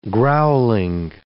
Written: growling

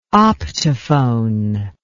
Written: optophone

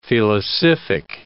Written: felicific

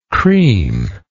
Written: creamlike

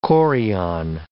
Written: chorion

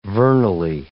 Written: vernally

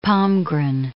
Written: Palmgren

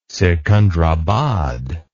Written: sikandarabad

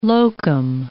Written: locum

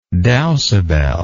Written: dowsabel

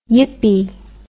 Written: yippy

